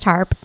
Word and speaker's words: Harpe: Did I say that right? – Just Harpe.